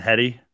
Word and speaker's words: Headey: Lena Headey, I believe it's pronounced. – Headey. – Yeah, she was tremendous.